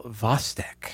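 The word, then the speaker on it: Chvostek: Ms. Annabelle Vostek. I believe I have that correct. Not how it's spelled. It's C-H-V-O-S-T-E-K.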